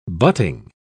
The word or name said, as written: butting